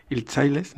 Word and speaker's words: Hiltzaile: Nos gustaría conversar un poco contigo acerca de esta campaña de BBVA Hilchailes, no sé si lo pronuncio bien o mal. – Muy bien.